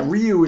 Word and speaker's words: Ryu: I was pronouncing it, as Ryu. – People are like, Ryu, okay. – Yeah, Ryu is supposed to be correct,